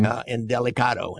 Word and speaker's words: Indelicato: Indelicato.